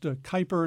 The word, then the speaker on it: Kuiper: It's K-U-I-P-E-R. I've heard it pronounced Kuiper, and I've heard it pronounced Kuiper. – Right, me too. – As you did. So take your pick, and you can do either one.